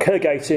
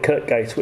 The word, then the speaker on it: Kirkgate: and Kirkgate in Leeds, or as Southerners call it, Kirkgate, which of course completely mispronounced it.